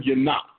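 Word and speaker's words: yanach: yanak, yanak.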